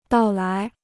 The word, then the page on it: 到来: dào lái